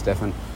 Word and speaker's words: Stephen: But I was watching the program about Stephen Curry, Stephen, I think they were saying, spelled Stephen, but pronounced Stephen, Curry, basketballer, NBA, and it's called Underrated.